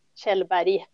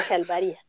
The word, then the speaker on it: Kjellberg: Okay, so for everyone who would like to try it, it's Kjellberg. Lena Kjellberg.